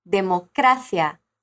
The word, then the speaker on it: democracia: Mas nas vezes que eu falei democracia, você percebeu que a sílaba tônica dessa palavra foi cra, democracia.